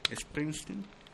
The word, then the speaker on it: Springsteen: Springsteen.